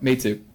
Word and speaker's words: Meitu: It's called MEITU is that's how it's spelled. – Me too.